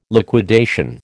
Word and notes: liquidation: American English

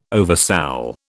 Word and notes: oversoul: British English

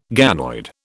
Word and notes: ganoid: British English

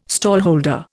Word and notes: stallholder: Indian

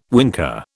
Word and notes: winker: British English

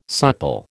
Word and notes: supple: American English

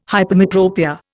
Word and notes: hypermetropia: Indian

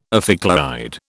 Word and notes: ophicleide: British English

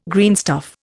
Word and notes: greenstuff: Indian

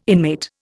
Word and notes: inmate: Indian

